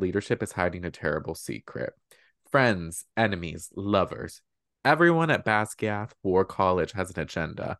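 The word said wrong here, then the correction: Basgiath: How do you say that? – Basgaiath. – Basgaiath. Oh my gosh.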